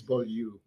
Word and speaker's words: Beaulieu: The French is Beaulieu. – Beaulieu. – But it sounds awfully pretentious.